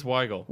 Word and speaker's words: Weigel: Is it Weigel or Weigel? – It's Weigel. Weigel. It is hard to tell on Twitter how it's pronounced.